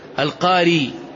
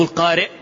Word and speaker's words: القاري: قال: حدثنا عبد الله بن عثمان القاري، مو القارئ، لاحظ الآن يمر بك عشرات ومئات، الرسم واحد، وهذا من المتفق والمفترق، الرسم واحد ولكن النطق يختلف. قاري نسبة إلى قبيلة القارة، قارئ نسبة إلى القراءة.